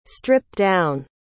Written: stripped-down

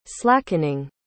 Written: slackening

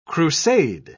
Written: crusade

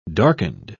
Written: darkened

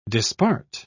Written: dispart

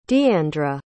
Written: deeandra

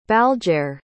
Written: balgaire

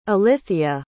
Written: ilithyia

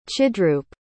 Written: chidroop

